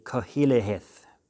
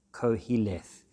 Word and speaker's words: Qoheleth: The other thing to mention about Ecclesiastes is that Ecclesiastes is the Latin version of the Hebrew word koheleth, which is spelled Q-O-H-E-L-E-T-H, koheleth, which basically means the teacher or the preacher.